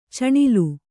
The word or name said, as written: ಚಿನಾಲಿ